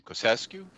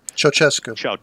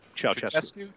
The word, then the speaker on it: Ceaușescu: And how do you pronounce his last name? Kosescu? – Ceaușescu. – Ceaușescu.